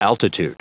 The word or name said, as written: altitude